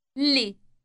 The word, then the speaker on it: لي: لي. لي. – Note the elongated L sound.